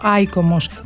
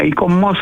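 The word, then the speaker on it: ICOMOS: Αν δεν το γνωρίζετε, αυτό ακριβώς κάνει το ΙΚΟΜΟΣ ή το, δεν ξέρω αν πρέπει να το πω, ΑΙΚΟΜΟΣ. Πρώτα απ' όλα καλησπέρα σας. – Καλησπέρα σας. Ένα ΙΚΟΜΟΣ θα το λέγαμε, γιατί προφέρουν όλο με τον γαλλικό τρόπο. – Και, με τον λατινικό είπατε.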